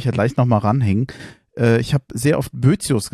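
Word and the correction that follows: Boethius: gesagt. Und auch da ist es wohl so, dass das richtig ausgesprochen, bo, richtig ausgesprochen Boetius heißt.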